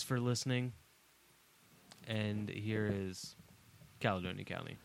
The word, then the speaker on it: Caledonia: All right, well, let's close out episode number 33 with, is it Caledonia County? How do you say it? – Calidonia County.